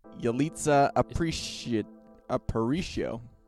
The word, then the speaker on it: Yalitza Aparicio: Yanitza Aprikosyan. Someone else read this. I'm going to butcher it. – Where is the category here? Oh, yeah. Yalitza Aparicio.